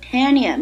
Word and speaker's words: tenure: 终身制，我们用词组 lifelong tenure， lifelong tenure， T-E-N-U-R-E tenure 名词，表示任期啊。 tenure 你听这个单词的发音像不像 tenure， tenure 十年，对不对？